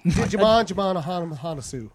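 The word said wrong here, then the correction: Djimon Hounsou: No, it's actually pronounced Jimon Honsu.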